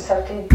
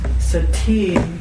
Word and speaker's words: sati: And so, sati, trying to remember the declension, feminine declension of sati. – Is it a long E or a short E? – Long E.